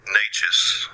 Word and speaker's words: Neches: So, sure enough, looking at this map, and there is a what they call Natchez Street in Oak Cliff, and that's spelled N-E-C-H-E-S, Natchez.